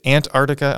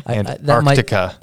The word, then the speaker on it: Antarctica: I felt like you were adding the hard C in there, Antarctica. – That might be my West Coast accent.